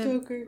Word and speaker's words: Stoker: So, song number 3 is Bloodbath and Beyond, which is based off of Dracula by, what is it, Bram? Stoker? How do you pronounce it? – Stoker.